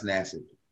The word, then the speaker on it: Nassib: Is that how we say his name? – Nassib. – Okay. – I think it's pronounced Nassib.